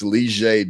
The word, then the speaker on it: Leger: And a lot of people look at my last name and think it's more challenging, but I think my first name, just because it doesn't sound how it's spelled, right? It's Leger Douzable. I think Douzable is a lot easier when you look at it phonetically on paper to say than Leger.